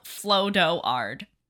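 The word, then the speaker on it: Flodoard: It's, if it makes you feel better, it's spelled Flo-do-ard. Floatard. – It doesn't. – But I could say Flo-do-ard for you.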